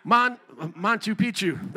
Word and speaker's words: Machu Picchu: what, how do you say that? Machu Picchu. There you go.